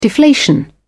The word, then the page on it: deflation: British English